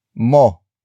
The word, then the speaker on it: mo: Word for word it's lapitan, mo, na, siya.